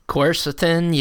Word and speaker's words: quercetin: And, you know, I also, you know, just searching on this compound, how are we pronouncing it? Quercetin? – Quercetin, yeah.